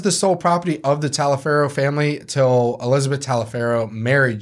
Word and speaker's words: Taliaferro: It's either T-A-L-I-A-F-E-R-R-O.